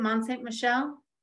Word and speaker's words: Mont Saint Michel: Mont Saint, is it how you say it? Mont Saint Michel? Is that how you say it? Yes.